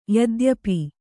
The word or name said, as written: ಯದ್ಯಪಿ